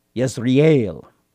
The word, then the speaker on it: Yezreel: Yezreel is probably the better Hebrew pronunciation.